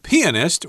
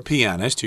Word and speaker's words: pianist: Uh, it's none other than Frederick Chopin, a Polish composer and pianist, or pianist, you can pronounce it both ways, who is widely regarded as one of the greatest musicians of the Romantic era.